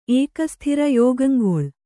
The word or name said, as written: ಏಕಸ್ಥಿರ ಯೋಗಂಗೊಳ್